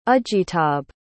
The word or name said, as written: ajitabh